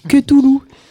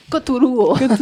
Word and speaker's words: Cthulhu: Alors, on pourra dire que ça se prononce pas forcément comme ça. – Ça dépend de la région. – Voilà. Ah ouais, moi, je dis toutes les lettres. Cthulhu.